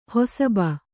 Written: ಹಸುಬ

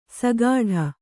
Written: ಸಗಾಢ

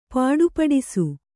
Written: ಪುಟುಪುಟಿಸು

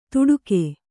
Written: ತುಡುಗೆ

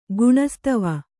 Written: ಗುಣಸ್ತವ

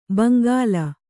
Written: ಭೋಂಕಲ್

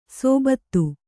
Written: ಸೋಬತ್ತು

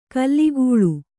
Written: ಖುಲಿಖುಲಿ

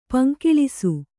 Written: ಪೊಂಗೆಲಸ

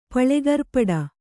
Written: ಪಳೆಗರ್ಪಡ

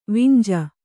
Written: ವಿಂಜ

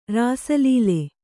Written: ರಾಸಲೀಲೆ

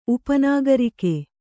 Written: ಉಪನಾಗರಿಕೆ

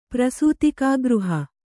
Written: ಪ್ರಸೂತಿಕಾಗೃಹ

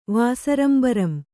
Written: ವಾಸರಂಬರಂ

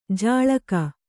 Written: ಝಳುಕು

